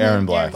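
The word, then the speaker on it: Erron Black: is it Aaron Black? – Mm-hmm. Aaron Black.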